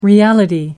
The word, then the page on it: reality: American English